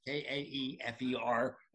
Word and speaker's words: Kaefer: Just Google Karl Kaefer. And yes, you pronounce it right at the beginning. I'm so pleased about that. And that, of course, is Kaefer and Karl with a K.